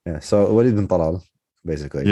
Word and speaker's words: bin Talal: And its chairman, who's a member of the royal family, Al-Walid bin Talal. I can never know how to pronounce Arabic names when speaking in English. Should I go like Al-Walid bin Talal? – Bin Talal.